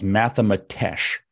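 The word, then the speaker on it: mathematx: And she's created a so-called ethnomathematics approach that she calls mathematesh, which is, that's how you say it, but it's mathemat. As if you're going to spell mathematics and you get to the T and then you just put an X. There's not even another vowel, just mathemat X, and it's pronounced mathematesh.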